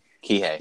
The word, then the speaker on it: Kihei: Kihei.